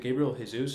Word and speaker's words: Gabriel Jesus: The biggest player that I was able to kind of take away from this is it Gabriel Jesus? Is that the right pronunciation? – Mm-hmm. Yeah.